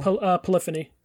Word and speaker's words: Polyphony: I'm probably going to say this name wrong. Polyphony Digital? – Polyphony. That's Gran Turismo. – Polyphony, yeah.